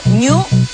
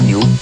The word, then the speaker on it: GNU: Por lo tanto, tenemos que pronunciar ñu. – Sí. – Hay gente que dice... – Sí, ¿por qué no? – G-N-U o... – G-N-U. Se pronuncia en castellano, ñu.